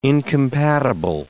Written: incompatible

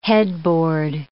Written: headboard

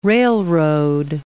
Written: railroad

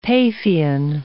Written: Paphian

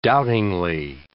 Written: doubtingly

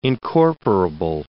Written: incorporable

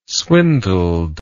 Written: swindled

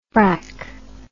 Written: brack

